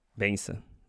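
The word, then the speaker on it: bênção: Benza. That's how you say it.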